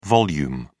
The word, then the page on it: vol: British English